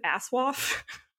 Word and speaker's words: ASOIAF: that they pronounce it Aswaf. – Nice. – I was like, I've always just said A-S-O-I-A-F, which is a mouthful, but Aswaf really rolls off the tongue.